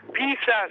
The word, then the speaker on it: Pisa: Poi ti sottolineo la vecchia diatriba, si dice Pisa o Pisa? Pisa secondo i pisani e Pisa secondo noi, e non siamo di Pisa.